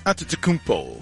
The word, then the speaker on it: Antetokounmpo: Giannis, the Greek freak Antetokounmpo. – There you go. – Wow, extra point for proper pronunciation. – Thank you. He gave me an extra point for pronunciation.